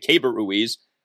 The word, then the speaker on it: Keibert Ruiz: We're now being told that's how you say it, Keibert Ruiz, as one of the top catching prospects in all of baseball.